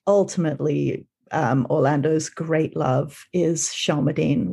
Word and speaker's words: Shelmerdine: I know people are wondering how Philip and, is it Shelmerdine? – Shelmerdine, who the name is taken from Virginia Woolf's Orlando.